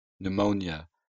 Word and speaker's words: pneumonia: Буква P не читается в следующих словах. – Cupboard. Psychology. Pneumonia. Raspberry.